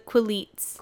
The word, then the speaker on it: Quileutes: I do want to say real quick, just so how do you say it? Quileutes? – Quileutes.